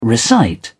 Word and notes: recite: British English

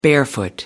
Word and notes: barefoot: American English

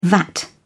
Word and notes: vat: British English

